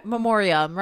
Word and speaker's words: memoriam: I think I got a little more emotional with that one just because it's definitely more of a like in memorial or how do you say that? – Memoriam, right? – Memoriam.